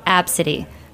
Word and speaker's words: Abcde: My favorite one is ABCD, which is ABCDE, which is pronounced Absidy.